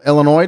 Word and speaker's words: Illinois: Today is National Illinois Day. Illinois or Illinois? That's always the debate. You people from Illinois, you tell me. I think the S is silent, right?